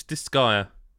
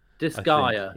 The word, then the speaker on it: Disgaea: Well, it's Disgaea. – Disgaea. – Yeah, Disgaea 7.